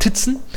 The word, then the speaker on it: Tizen: Tizen oder Tizen, wie man es vielleicht im Deutschen aussprechen würde, ist der ja, inoffizielle Nachfolger, würde ich sagen, oder einer der Nachfolger von MeeGo.